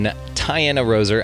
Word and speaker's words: Tianna Roser: All right, ladies and gentlemen, extremely excited to welcome Tianna Roser.